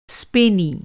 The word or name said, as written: स्पेनी